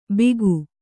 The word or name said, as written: ಬೀಗು